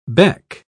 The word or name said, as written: beck